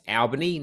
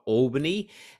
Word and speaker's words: Albany: My actual hometown is Albany, which is about 400k south of Perth, pronounced Albany, not Albany. So that's an important distinction.